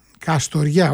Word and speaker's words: Καστοριά: Τρίτος όμιλος, Ξάνθη Πανθρακικός, Νάουσα Ορέστης, Καστουριά, Καστοριά, όχι Καστουριά, το είπα πολύ με προφορά, έτσι ντόπιο λαλιάς.